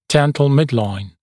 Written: dental midline

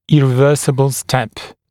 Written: irreversible step

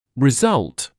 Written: result

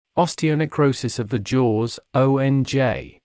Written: osteonecrosis of the jaws

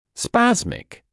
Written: spasmic

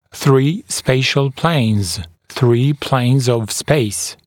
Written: three spatial planes, three planes of space

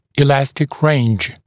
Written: elastic range

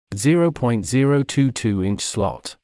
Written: 0.022-inch slot

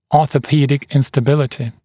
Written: orthopedic instability

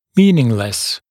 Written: meaningless

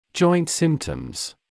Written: joint symptoms

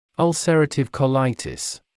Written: ulcerative colitis

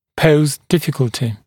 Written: pose difficulty

